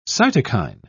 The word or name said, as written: cytokine